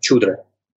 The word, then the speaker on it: шудра: Кстати, в Индии там есть шудра, произносится тоже как чудра, такое ше, ближе к че.